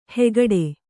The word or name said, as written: ಹಾಗಡ